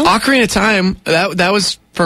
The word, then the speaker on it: ocarina: I want to say Ocarian. – No, it's an ocarina.